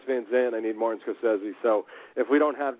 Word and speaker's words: Scorsese: Scorsese, actually, you know, which all my guys pronounced it wrong in the scene, which was a little funny because Scorsese says, "Hey, it's Martin Scorsese," and then everyone else calls him Scorsese.